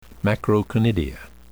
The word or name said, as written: macroconidia